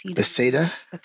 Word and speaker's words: Bethesda: 54 to 59, he worked at the National Institute of Mental Health in Bethesda, Maryland. I think I'm pronouncing that correctly. – Bethesda.